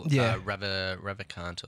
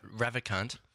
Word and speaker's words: Ravikant: Neville Ravikant or something.